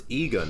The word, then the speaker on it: Iga: So it's Iga, not Iga.